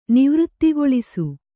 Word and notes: ನಿವೃತ್ತಿಗೊಳಿಸು: Kannada

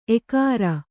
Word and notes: ಏಕಾರ: ēkāra

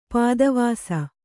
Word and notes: ಪದಾವಾಸ: padāvāsa

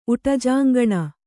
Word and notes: ಉಟಜಾಂಗಣ: kannada

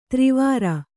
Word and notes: ಥರಾವರಿ: Kannada

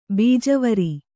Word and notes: ಬೀಜವರಿ: bījavari

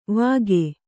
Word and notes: ವಾಗೆ: vāge

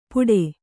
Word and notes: ಪುಡೆ: Kannada

